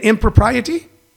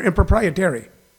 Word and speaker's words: impropriety: Impropriety? Why do I want to put an extra R in there? Improprietary. Impropriety.